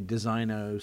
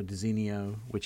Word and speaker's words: Designo: I drove an ML when they still made the Mercedes-Benz ML, and it had the Designo seats or Designio, however you want to pronounce it, seats inside.